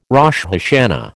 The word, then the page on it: Rosh Hashana: American English